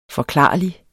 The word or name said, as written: forklarlig